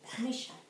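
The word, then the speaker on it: Meshech: The sons of Japheth were Gomer and Magog, and Madai, and Javan, and Tubal, and Meshesh, and Taras. – Meshach. – How do you say it? – Meshach. – Okay. What she said.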